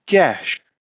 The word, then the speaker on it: geas: So, we've gone with a really fucking shitty fifth level spell um, called Gesh. I think it's pronounced. At least that's what I looked it up anyway. Spelt G E A S because I can spell.